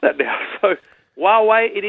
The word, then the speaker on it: Huawei: How do you say this? Huawei. – If I had to take a guess, Ditz, I'd say Huawei. – Oh, you've got inside info. Let's get our expert on, Trevor Long. Trev, all the way from San Jose. Good morning. – You've nailed it. Huawei it is.